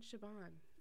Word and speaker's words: Chabon: Which I also listened to the Supernatural Then and Now podcast about this episode, and they say John Chabon as well. So we've been saying it right, besties.